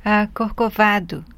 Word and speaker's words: Corcovado: Corcovado, from the Getz Gilberto record. Did I say that wrong again, Astrid? – Corcovado. Okay. The just the accentuation was in the wrong syllable, that's all.